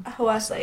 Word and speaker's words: Hwasa: If you guys do not know, listen to Maria by Hwasa. Is that how you say her name? – Hwasa, yeah.